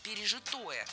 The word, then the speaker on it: пережитое: Хотя в русском языке есть еще слово «пережитое» с ударением на четвертом слоге.